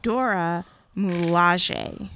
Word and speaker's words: Dora Milaje: I looked up the pronunciation, and I think it is supposed to be Dora Milaje.